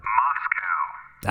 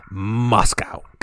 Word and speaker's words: Moscow: Moscow. – Ah, you're good. – Yeah, Moscow.